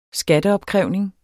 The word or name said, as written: skatteopkrævning